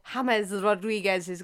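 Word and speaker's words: James Rodríguez: Cahill's goal v Netherlands or James Rodriguez's goal v Uruguay at 2014 World Cup? – Beautiful pronunciation.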